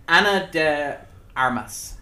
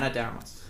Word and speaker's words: Ana de Armas: It's Ana de Armas. – Perfect. – Ana de Armas.